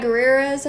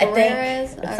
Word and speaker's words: Gurira: And it's Danai Gurira's, I think is how it's pronounced. Gurira's.